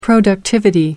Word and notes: productivity: American English

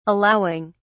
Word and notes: allowing: British English

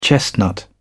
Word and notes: chestnut: British English

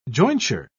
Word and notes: jointure: British English

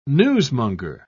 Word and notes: newsmonger: British English

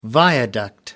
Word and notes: viaduct: American English

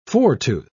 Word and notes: foretooth: British English

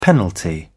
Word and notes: penalty: British English

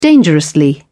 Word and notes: dangerously: British English

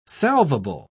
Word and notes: salvable: British English

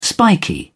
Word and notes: spiky: British English

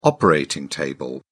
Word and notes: operating table: British English

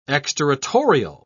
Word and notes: exterritorial: British English

